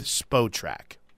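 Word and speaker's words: Spotrac: Now, do you go with SpotRack or do you go with SpoTrack? – SpoTrack. – Really? I can't believe that I've been the guy that's never said that right. Okay, so I have SpoTrack, which is running incredibly slow today, pulled up.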